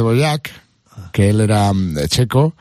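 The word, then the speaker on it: Dvořák: que lo has pronunciado de una forma sí, bueno, es que cada uno lo pronuncia un poco de Boyac, pero bueno, es de Borac, para quien lo quiera buscar, de Borac.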